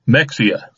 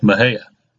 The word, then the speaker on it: Mexia: there's a little town called Mejia, and that would be the Spanish pronunciation. But if it's spelled M-E-X-I-A, Mexia. So most people who don't have some kind of a handle on Spanish pronunciation would call it Mexia, but it's Mejia.